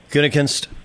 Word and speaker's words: Gutekunst: He doesn't care for, is it Guten? How do you pronounce his last name? – Gutekunst.